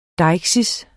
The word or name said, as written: deiksis